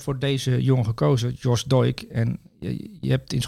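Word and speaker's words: Doig: Zeg ik het goed? Ik denk dat je een Schotse uitspraak toch? Ja, Doik.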